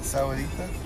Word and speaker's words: Sahuarita: Is that how you say it? Sahuarita? – Yeah.